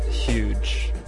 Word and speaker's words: Hooj: speak of very influential pioneering record labels, we said Bedrock, but we've got to talk about Huge. Huge Tunes is a classic label. It's spelled H-O-O-J, based out of Europe, and they're not around anymore, sadly.